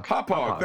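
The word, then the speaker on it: Hauppauge: Hoppage.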